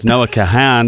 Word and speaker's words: Noah Kahan: So it's Noah Kahan, not Noah Kahan. Because it's spelled K-A-H-A-N. – Yeah. – Kahuta. Noah Cahoots. – No, it's not Noah Can't. It's Noah Kahan.